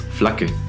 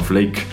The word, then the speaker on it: Flake: entonces él prefiere que su apodo se pronuncie como se pronunciaría en alemán, que es Flake, no Flake.